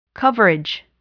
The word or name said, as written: coverage